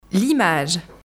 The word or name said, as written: l'image